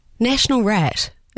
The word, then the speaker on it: Nationalrat: Bundestag, Nationalrat, Bundesrat. – Nationalrat.